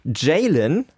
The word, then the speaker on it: Jaln: Jalen, das wird so ausgesprochen, wurde mir gesagt, J-A-L-N geschrieben, 23 Jahre alt aus Köln, interessanter Künstlername, deutscher Pop und Soul ist der Musikstil von Jalen.